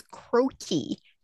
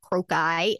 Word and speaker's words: croci: Another word that I would have mispronounced that day was croaky. Did you know that the plural of crocus is not croci? It's pronounced croaky.